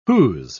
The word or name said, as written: who's